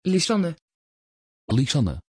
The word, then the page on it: Lysanne: Dutch